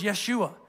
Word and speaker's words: Yeshua: In the Old Testament, it was the Hebrew word is Yeshua. That's how they pronounced it back in Joshua's day.